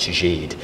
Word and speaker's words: Gide: who, you know, was working class Polish, who would teach me how to pronounce the names of Kwasowski, or even I remember saying Gide, and he's like, it's Gide, mm.